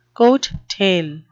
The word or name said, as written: coattail